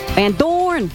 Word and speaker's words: Van Dorn: Okay, how do you say it? – Van Dorn. I'm just going to say it the way I always say it.